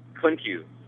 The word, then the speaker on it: Klymkiw: Klymkiw. – Klymkiw. And can you spell that for everyone? – K-L-Y-M-K-I-W.